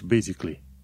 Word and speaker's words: basically: Se scrie, de exemplu, basically, basically. Și când îl pronunți, zici basically. Al doilea A nu este pronunțat.